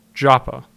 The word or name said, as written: Joppa